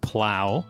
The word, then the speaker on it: plough: I didn't correct you because I wasn't sure, but P-L-O-U-G-H is pronounced plow. – Oh. – It's just an English spelling, I believe. So just everyone who is listening to this episode, re-listen to that paragraph again, and then in your mind, say plow instead of plough. – I don't know, it looked like plough. – I know, I mean, it's spelled P-L-O-U-G-H, I get it. But it is apparently plow.